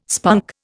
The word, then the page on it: spunk: Indian